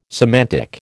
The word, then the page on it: semantic: American English